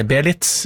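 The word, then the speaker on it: Beelitz: Vi var i Tyskland, i Belize, Belitz, kanskje riktigere uttale, Belitz i Deutschland.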